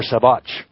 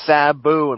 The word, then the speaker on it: Sabu: Is that Sabu or Sabotch? – It's Sabu, and pronounce it right.